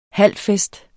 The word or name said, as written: halfest